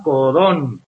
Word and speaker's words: codón: Para que sepáis todo eso, esto se llama codón, con acento en la O. Codón, ¿de acuerdo?